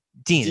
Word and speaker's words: digne: digne digne Dean, Dean. is how it's pronounced.